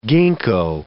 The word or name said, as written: gingko